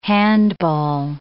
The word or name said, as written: handball